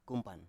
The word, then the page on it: kumpan: Biak